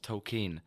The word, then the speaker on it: Tolkien: It's pronounced Tolkien. – Tolkien. We've all been doing it wrong.